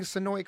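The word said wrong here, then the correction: Senoia: correctly? – Oh, it's close enough. – Well, don't be not close enough. How do you say it? – Sonoya. – Sonoya. Okay, great.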